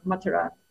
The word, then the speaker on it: Mathura: And usually we hear that Krishna was born in Mathura, or Mathura is the more correct pronunciation.